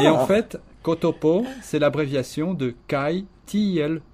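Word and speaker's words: ktp: Et puis ces trois petites lettres que l'on prononce en espéranto, kotopo, pour dire...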